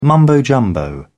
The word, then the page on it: mumbo jumbo: British English